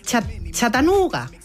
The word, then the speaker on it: Chattanooga: Nos vamos a Chattanooga.